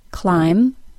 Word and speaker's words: climb: Climb, doubt, debt, bomb. And here are the 2 words with a silent G. Campaign, design.